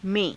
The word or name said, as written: me^